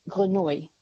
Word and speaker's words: Grenouille: Is it G-R-E-N-O-U-I-L-L-E? – U-I-L-L-E. – I think if it's the French word, it's Grenouille. – Grenouille, thank you. – Yeah. – Because it is the French word, but not pronounced very well.